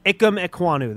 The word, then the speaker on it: Ikem Ekwonu: I don't want to try and, I'm going to butcher his name. Ikem Ekwonu? – Yeah. – Tried that, sorry. – Ikem Ekwonu, that's correct.